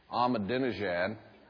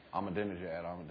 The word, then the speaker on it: Ahmadinejad: And there's that little guy, it took me about 3 months to learn to say his name, Ahmadinejad. Ahmadinejad, you know.